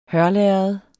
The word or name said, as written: hørlærred